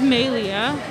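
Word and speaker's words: MaeLea: Yeah, my name is Maylia. Everyone calls me May. My full name is spelled capital M-A-E capital L-E-A.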